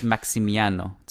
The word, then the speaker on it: Maximiano: Yeah, it's Maximiano. There's no L.